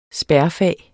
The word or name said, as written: spærfag